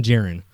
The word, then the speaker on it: Djarin: But like I said, it's we don't get his name until like 6 or 7 episodes into the season, and it's Din Djarin. Mm-hmm. So the D is silent, I guess.